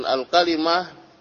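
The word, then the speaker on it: الكلمة: Qala al-kalimah qawlun mufrad dan al-kalimah bifathil qaf wa kasril lam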